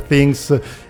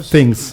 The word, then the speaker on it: Things: Stranger Things e quant'altro. Vado bene con la pronuncia che mi batte un po' sul ponte? Eh, things. Più, più, things. più Stranger Things.